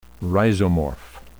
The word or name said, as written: rhizomorph